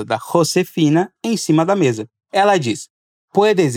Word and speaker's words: mesa: Que em português quer dizer, você pode deixar as suas coisas sobre a mesa. Aqui, quero chamar a sua atenção para a pronúncia do S, nas palavras cosas e mesa. O som é de S mesmo, não de Z como em português.